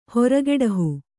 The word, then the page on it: ಹೊರಗೆಡಹು: kannada